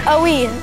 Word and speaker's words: ennui: Ennui.